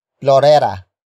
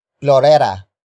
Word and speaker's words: plorera: In this context, nang is an object marker that introduces what you're supposed to search for, and that is plorera. Plorera. Plorera. Plorera is spelled as P L O R E R A.